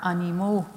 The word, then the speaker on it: animaux: Remember that one animal is an animal, but more than one are animaux, ending in A-U-X. Do not pronounce the X. These animaux live on a ferme.